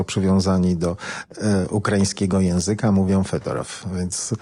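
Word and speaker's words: Fedorow (Федоров): W wymowie ukraińskiej to będzie Fedorow. Natomiast ci wszyscy, którzy mówią w języku rosyjskim dla różnych serwisów, blogów i kanałów internetowych, używają rosyjskiej wersji i to jest Fiodorow.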